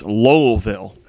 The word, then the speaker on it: Lowellville: that the correct pronunciation is Lowellville. And I'm, you know, because you got to throw that whole L in there, you know, like, or that was just her thing.